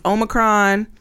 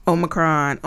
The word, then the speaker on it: Omicron: So it is Omicron, Omicron, Omicron. That is how you say the letter.